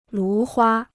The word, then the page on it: 芦花: lú huā